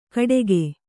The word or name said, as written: ಕಡೆಗೆ